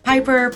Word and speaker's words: Pipper: You are listening to Your Commute with Piper. Piper is spelled P-I-P-P-E-R. I realize that it's got two P's, but that's just how I chose to spell it. So it's Piper, P-I-P-P-E-R.